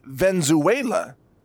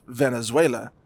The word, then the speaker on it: Venezuela: Although there's a silver lining in this invasion of Venezuela, and it could be that maybe some Americans would stop saying Venezuela, and maybe they would learn how to pronounce the country name correctly, because it is, of course, Venezuela.